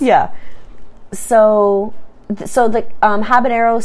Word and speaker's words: Habanero: Caribbean Red Habanero. No. How is that pronunciation? – Nailed it. – Okay, good.